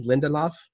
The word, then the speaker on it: Lindelof: Lindelof.